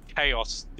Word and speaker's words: Chaos: Like, I think I talked about the difficulties before, and, like, once you beat the game, the normal game, you unlock Chaos difficulty, which... – You said it wrong. – ...starts at level one. – You gotta say it right. Yeah, thank you, yes. Chaos!